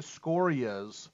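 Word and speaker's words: Sciorra: Is that how you say it? Scoria? – I believe so.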